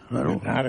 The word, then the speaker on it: Bernhardt: Tienes que pronunciar francesa. – Bernard, entonces Bernard, ¿no?